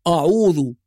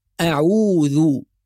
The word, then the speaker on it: أعوذ: يعني مثلا إذا فخمنا الهمزة وهذا نطق خاطئ، فقلنا آه، بالتالي نفخم العين ونفخم الذال فنقول أعوذ، وهذه كلها أخطاء شائعة في تلاوة القرآن الكريم. يبقى إذن الصواب مستمعينا الكرام أن نقول أعوذ.